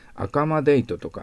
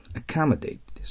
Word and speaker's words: accommodate: これで accommodate はアクセントを気をつけないと覚えたうちに入りません。アカマデートとかアコモデートなんてやったんじゃもう英語になりませんから、アカマデートです。そして最後はデートとならないようにしてください。